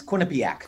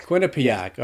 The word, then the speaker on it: Quinnipiac: So let's go back to, am I saying this right? Is it Quinnipiac University? – It's Quinnipiac. – Quinnipiac. All right.